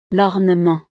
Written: l'ornement

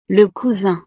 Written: le cousin

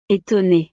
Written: etonne

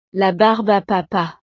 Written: la barbe a papa

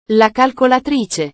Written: la calcolatrice

